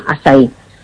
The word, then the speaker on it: açaí: Si lo queréis buscar escrito, que se escribe A con la cedilla, como si fuera una portuguesa, y se llama eso acai orgánico, y se pronuncia açaí.